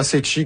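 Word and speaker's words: Ceci: Oui, Cecchi, pas Cecchi, comme j'ai dit.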